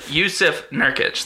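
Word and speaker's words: Jusuf Nurkić: I'm going to butcher his name like I always do. Yusuf Nurkic. Yusuf Nurkic. Thank you.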